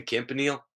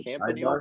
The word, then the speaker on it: Campanile: But apparently we pronounce Campanile wrong. It's supposed to be pronounced a different way, but Tech pronounces it wrong.